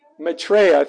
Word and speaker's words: Maitreya: How do you pronounce that? Maitreya, thank you.